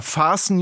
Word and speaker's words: fasten: Wenn Sie sich im Flugzeug anschnallen müssen, dann steht da »Fasten your seatbelts«, schließen Sie Ihren Sicherheitsgurt. Geschrieben wie unser Fasten, das T darin wird nur nicht ausgesprochen.